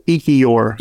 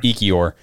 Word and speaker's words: Ekiyor: Emil Ekior. – Ekior. – E-K-I-Y-O-R.